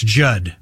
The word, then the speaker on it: Judd: That's Judd. With two D's, the second D is silent.